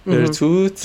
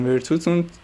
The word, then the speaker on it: ورتوت: من تلفظشو ورتوت. آره ورتوت، جردن ورتوت. – آره جردن ورتوت.